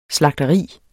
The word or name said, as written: SLAGTERIERNES